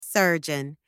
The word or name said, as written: surgeon